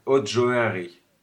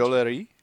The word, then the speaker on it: joaillerie: Joaillerie. Okay. – No, it's actually tricky to pronounce for English people.